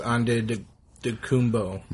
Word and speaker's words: Antetokounmpo: I learned today that the Greeks, for the letters T, sometimes make it sound like a D, and for P, sometimes sounds like a B. – Yeah. – And I learned this because there's an NBA player whose last name is Antetokounmpo, and I heard it's actually pronounced Antetokounmpo.